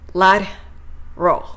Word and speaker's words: lateral: ジェネラルであれば general general セブラルであれば several several ラテラルであれば lateral lateral と発音します。 R から L への音移動、ぜひマスターしてこの辺りの単語かっこよく発音できるようにしてみてくださいね。